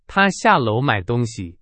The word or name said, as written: 下楼